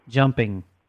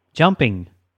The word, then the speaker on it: Jumping: Jumping. – Ira what? – Jumping. – How do you spell that? – Just like the word, J-U-M-P-I-N-G.